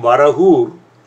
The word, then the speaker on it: Varahur: In Tamil, it became Varahur, and today, due to a bad spelling, it is spelt as Varagur, like V-A-R-A-G-U-R, Varagur, in all over the place.